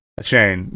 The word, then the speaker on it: Achane: Achane. Yep. – Achane, yeah. I know he came out and like corrected, hey, now that I'm here, here's how you pronounce it.